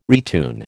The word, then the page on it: retune: American English